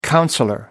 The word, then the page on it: councillor: American English